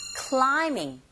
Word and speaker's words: climbing: Now try the second listening point, that is climbing the tree with the basket. Climb, 여기서 부 사운드 묵음인 거 아시죠, 여러분?